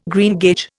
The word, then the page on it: greengage: Indian